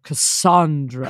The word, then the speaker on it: Cassandra: She's like, it's pronounced like son, not sand. – Oh, Cassandra.